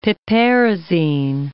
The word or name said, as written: piperazine